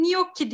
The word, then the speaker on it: gnocchi: Evet şimdi ikinci yemeğim gnocchi diye okunuyor.